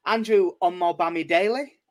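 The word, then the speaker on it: Omobamidele: Andrew Omobami-Daly, I think is how he pronounced it. – It is, yes. You've nailed that one.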